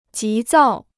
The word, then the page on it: 急躁: jí zào